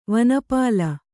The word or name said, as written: ವನಪಾಲ